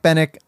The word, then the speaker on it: Benwick: Not Benwick, yeah, which is how it's spelled, but Bennick.